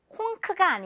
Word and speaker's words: honk: 이 N 부분은 이응이고요. honk가 아니라 honk 이렇게 발음돼요. honk.